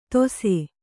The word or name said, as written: ದೋಸೆ